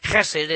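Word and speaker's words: chesed (חסד): It's actually pronounced chesed. It's got a rough guttural sound to it. Most people don't like it when you say it that way, but that's how you say it, chesed.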